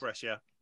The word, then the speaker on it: Brescia: This is going to be an appalling pronunciation. Brescia. – It is Brescia.